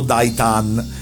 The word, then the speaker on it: Daitarn: Daitan viene scritto Daitarn e correttamente pronunciato Daitan.